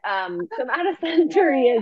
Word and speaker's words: somatosensory: Somatosensory.